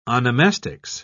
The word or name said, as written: onomastics